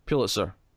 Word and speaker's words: Pulitzer: Is it Pulitzer or Pulitzer? Because I've heard it both. – Let me say it without thinking about it. Pulitzer. That's how I'd say it.